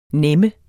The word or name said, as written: nemme